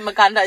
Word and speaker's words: maganda: Wait, I was trying to think of maganda. Is that how you say it? – Yeah, no, that's beautiful.